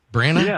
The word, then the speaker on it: Branagh: Branagh? – Yeah.